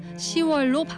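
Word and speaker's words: 시월: 사실 한자만 놓고 보면은 열, 십에 달, 월이 합쳐진 말이니까 10월이라고 하는 게 맞을 것 같잖아요. – 예? – 하지만 10월이라고 발음해 보면 어딘가 좀 불편하지 않으신가요? 그래서 발음하기 더 편한 10월로 바뀌어 굳어진 겁니다.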